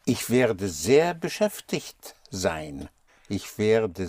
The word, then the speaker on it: werde: There are two syllables, but you only stress the first one here. – Werde. – Was more? – Ich werde. – But I didn't hear the second syllable.